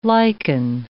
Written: lichen

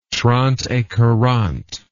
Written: trente-et-quarante

